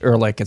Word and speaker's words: Ehrlich: the very last clip, I think his last name is pronounced Ehrlich. It's Paul Ehrlich.